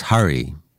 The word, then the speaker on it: Harry: Notice he says Harry, like the prince. My name's pronounced Hurry. Shop's given name is actually spelt S-W-A-P-A-N, and for years he let people mispronounce it, Swapan.